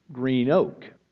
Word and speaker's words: Greenock: Spelled Greenock, one word, Greenock. The locals pronounced it Green Oak. So you could always tell who was a local and who wasn't, and I could never understand why you got Green Oak. Across the river was White Oak, which was spelled W-H-I-T-E, space, capital O-A-K, which makes perfect sense to me.